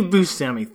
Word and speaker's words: Buscemi: Directed by Steve Buscemi.